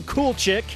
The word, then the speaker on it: Kulczyk: It's spelled K-U-L-C-Z-Y-K, so I get that, but it's pronounced cool chick.